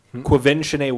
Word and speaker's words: Quvenzhané: You have Quvenzhané Wallace.